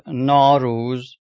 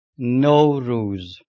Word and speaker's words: Nowruz: Is not Nowruz. It is pronounced Nowruz.